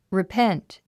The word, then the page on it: repent: ripént; リィペェント